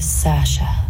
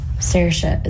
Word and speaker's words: Saoirse: It's Saoirse, but it's easier for Americans and English people to say Saoirse or Sasha. – What was the difference? – Saoirse is how Irish people say it.